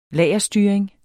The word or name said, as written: lagerstyring